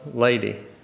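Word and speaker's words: Ledi: Her name was Lady, spelled L-E-D-I, I believe, but pronounced Lady.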